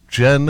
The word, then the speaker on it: Jyn: J-Y-N, pronounced Jen as in the drink.